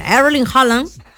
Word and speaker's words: Erling Haaland: Después de Cristiano, fue Erling Haaland. Ahí está, lo dije bien, Erling Haaland.